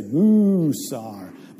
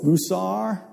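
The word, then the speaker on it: musar (מוסר): That word in the Hebrew, now in Texas you would hold the first syllable, so you would say musar. But in the Hebrew, what? It's musar, right? You know, their syllables on the second accent, right? That's where they hold it.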